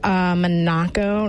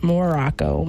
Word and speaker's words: Monaco: Another one is, Monaco, not Morocco, Monaco.